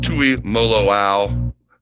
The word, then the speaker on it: Tuimoloau: Let's start right at the top, JT Tuimoloau. How'd I do in my pronunciation there? – Perfect, perfect pronunciation.